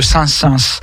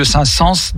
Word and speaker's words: Saint-Saëns: Et donc là, je vais parler de Camille de saint sens de saint sens pardon, faut le prononcer correctement. Camille de saint sens donc qui est un compositeur du 19e siècle, tardif.